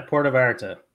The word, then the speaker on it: Puerto Vallarta: They're going to go enjoy some time down there in Puerto Vallarta. Did I say that right? – Yeah, Puerto Vallarta.